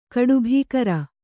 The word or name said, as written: ಕಡುಭೀಕರ